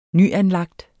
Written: nyanlagt

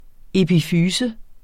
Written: epifyse

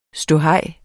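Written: ståhej

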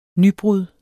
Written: nybrud

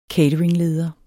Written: cateringleder

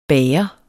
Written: bager

